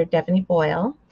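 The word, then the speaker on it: Devony Boyle: You can find me there under Devonie Boyle.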